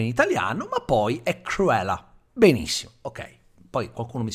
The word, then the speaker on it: Cruella: È per questo che eh, sto caricando la pronuncia, perché non è Cruella, come lo diremmo all'italiano, è Cruella.